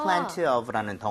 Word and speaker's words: plenty of: 에 따라서 여러분들 plenty 대신에 t를 약화시켜서 발음 안 하는 경우도 많아요. 그래서 plenty of. – Plenty of. – 그런 식으로 가도 괜찮습니다.